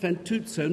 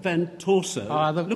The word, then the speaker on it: Fantuzzo: This year, we'd like to award the Packing Room Prize on behalf of the Packers and me to Vincent Fantuzzo for his portrait of Matt Moran. And they are here. – Slight pronunciation. Vincent Fantuzzo, not Fantuzzo. Look at him.